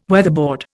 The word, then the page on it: weatherboard: Indian